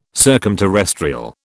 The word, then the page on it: circumterrestrial: British English